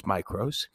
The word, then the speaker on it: mikros: Um, I'm not the best with Greek pronunciation, so it could be either micros or micros, but I think it's micros, spelled M I K R O S. Lots of C's are actually hard K's in Greek, as well as C's are also pronounced as K's in Latin, as in Lycaicus, Caecilius, Kikro, Kaisar, and so on.